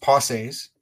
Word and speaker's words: Pensées: It's called Parker's Pensies. And Parker's Pensies is an homage to French philosopher theologian Blaise Pascal and his famous book, The Pensies. Now, I know that it's pronounced Pensées in French.